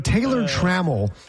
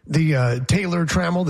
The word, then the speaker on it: Taylor Trammell: The alliteration, Taylor Trammell, the TT, Taylor Trammell. There's two syllables, Taylor Trammell, and then it goes down and up.